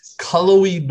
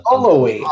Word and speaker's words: Cullowhee: And how do we pronounce the town that Western Carolina is located in? Cullowhee, North Carolina. Cullowhee.